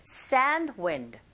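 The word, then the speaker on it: sandwich: 아니라 sand wind, sand wind. 이렇게 앞부분에 강세 주는 부분을 조금 길게 발음하시면 자연스럽게 드 사운드는 약하게 들릴 수가 있죠.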